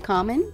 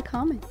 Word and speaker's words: Karmen: On social media, I am at Nikita Karmen. I know that it's hard to understand what I'm saying with my last name because I don't pronounce R's because I'm Australian.